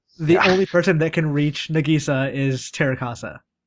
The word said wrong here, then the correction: Terasaka: You know, we've been pronouncing that wrong the entire time. It's actually Terasaka.